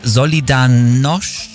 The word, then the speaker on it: Solidarność: Der das russische Regime mit seiner Solidarność... – Ja. – ja, so würde man es aussprechen, ja, erfolgreich gestürzt hat und dann Polens erster demokratisch gewählter Präsident wurde und für sein Engagement den Friedensnobelpreis bekommen hat.